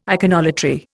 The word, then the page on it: iconolatry: Indian